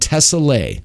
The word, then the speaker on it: Tessellae: This producer is called Tessellae. T-E-S-S-E-L-L-A-E. Okay? Just remember Tessellae phonetically. That's all you need to do.